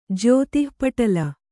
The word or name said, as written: ಜ್ಯೋತಿಃಪಟಲ